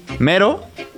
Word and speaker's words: Mehro: Mero. Is it the correct pronunciation of your Perfect. Como el mero mero.